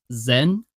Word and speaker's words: Dzyen: It's Zen, pronounced Z-E-N, but it's spelled D-Z-Y-E-N.